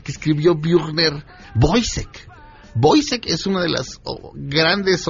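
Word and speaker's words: Woyzeck: Boisek, se escribe Woyzek.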